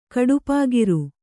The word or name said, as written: ಕಡುಪಾಗಿರು